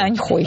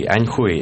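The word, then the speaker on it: Аньхуй (安徽): Слово звучит как хуй. Аньхуй. Это правильное китайское звучание. По-русски пишется без звука и оборот.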